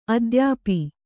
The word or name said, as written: ಅದ್ಯಾಪಿ